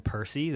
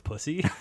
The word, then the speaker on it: Percy: But the best is with Thomas, there's a character named Percy that every kid mispronounces and calls it Pussy.